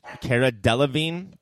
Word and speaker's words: Cara Delevingne: Is Cara Delevingne? Is that how you say that? – Yes. You're pronouncing correctly.